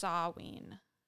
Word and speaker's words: Samhain: A Gaelic word pronounced Samhain. Samhain. Yeah. – Whatever you want. – It's spelled Samhain, but apparently it's pronounced Samhain.